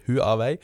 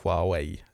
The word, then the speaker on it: Huawei: Nei, Helmer kunne opplyse om at Huawei det har altså 2 stavelser, ikke 3, det ikke Huawei, men men to stavelser, altså mer I retning av Huawei der omkring.